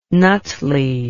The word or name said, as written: Nutley